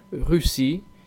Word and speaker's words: Russie: Recuerden que las seis finales no suenan, por eso decimos Russie y music.